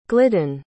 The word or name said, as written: glidden